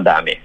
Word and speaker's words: Dame: No, se llama Dame, bien en español, bien chileno.